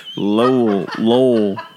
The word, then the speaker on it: Lowell: It was like, this guy's name is LOL. – Lowell. It's like, who is Lowell? – Laugh out loud. No, it's like a name, Lowell.